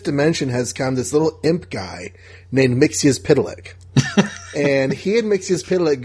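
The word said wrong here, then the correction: Mxyzptlk: Excuse me. It's Mixilplick.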